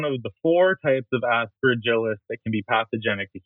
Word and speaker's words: Aspergillus: So Aspergillus.